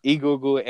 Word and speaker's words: Egungun: Egungun. Did I pronounce that right? Egungun. – Yeah, Egungun, Egungun. How you pronounce your E's.